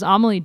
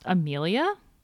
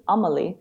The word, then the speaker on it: Amelie: Actually, I think I was talking to Taylor Wiles, and I believe her name is pronounced Amelia, which I've been saying it wrong forever. – Well, in the way her name's spelt in French, it's Amelie.